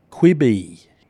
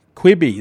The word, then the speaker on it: Quibi: It's available for download in the Apple and the Google Play Store at the moment, and it's pronounced Quibi. Quibi, there you go.